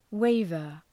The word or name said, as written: waiver